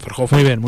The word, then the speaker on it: Verhoeven: Por ejemplo, la banda sonora del Libro Negro, la película de Paul Verhoeven. ¿Eh? ¿Se pronuncia así? Sí. Verhoeven. – Muy bien.